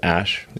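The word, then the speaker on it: Æ: It's just X, the letter X. And then the AE is like pronounced Ash. And then A12 is my contribution.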